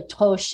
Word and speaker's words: troche: Or you can take it as a troche, or it's really toche in French, but we Americans butcher the pronunciation.